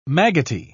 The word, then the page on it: maggoty: British English